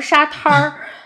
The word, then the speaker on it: 沙滩儿: 而且沙滩这个地方就是在景山公园的东边，你就觉得，哎，景山公园旁边竟然还有个沙滩，但其实它念成沙滩儿。